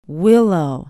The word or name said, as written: willow